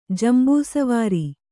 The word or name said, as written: ಜಂಬೂಸವಾರಿ